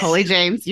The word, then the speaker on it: Colie James: Oh my gosh, did I say your name right? – You did, girl. Okay. It's Colie James. You did. Good job.